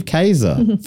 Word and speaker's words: Kaiser: This one was written by Steve Kaiser. Rhymes with laser. Steve Kaiser from Sydney, Ohio.